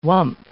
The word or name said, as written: whump